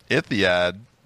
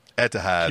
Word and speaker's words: Etihad: ITHAD. ITHAD? Etihad.